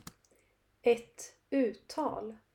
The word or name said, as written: ett uttal